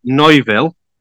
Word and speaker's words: Neuville: And then he moved, played with Oliver Neuville, which is spelt Neville, but with a U between the E and the V to help you, in 2001.